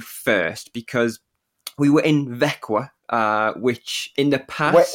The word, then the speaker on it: Växjö: Växjö.